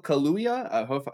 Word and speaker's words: Kaluuya: produced by Daniel Kaluuya. How do I say his name? How do I say that? – Decently close. – Was it? Nice.